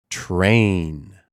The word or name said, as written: Train